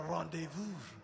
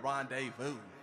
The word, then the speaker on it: rendezvous: My friend from Paris told me she hates the way I pronounce rendezvous. She's like, it's a rendezvous. I'm like, girl, you French, girl. I'm from Inglewood. We call it rendezvous.